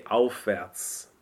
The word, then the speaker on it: aufwärts: Which is really hard to pronounce an F and a W. – Well, it's an F and phonetically an F and a V. – And a V, right? – Mm-hmm. – So you usually say aufwärts. So more or less drop the V sound.